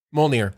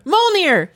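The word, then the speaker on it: Mjölnir: Mjolnir. – Mjolnir.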